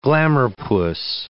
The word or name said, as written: glamour-puss